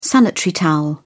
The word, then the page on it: sanitary towel: British English